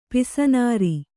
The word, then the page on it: ಪಿಸನಾರಿ: Kannada; pisanāri